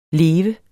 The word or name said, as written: leve